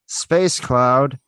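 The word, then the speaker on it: Space Cloude: Sure, you can check out my podcast, Space Cloud, the E is silent.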